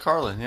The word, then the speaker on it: Carlin: George Carlin, yeah.